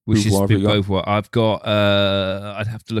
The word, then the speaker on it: Beauvoir: I can't say her name properly. – Beauvoir. You nailed it, yeah.